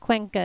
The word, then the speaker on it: Cuenca: So we think Cuenca is right. It's spelled C-U-E-N-C-A.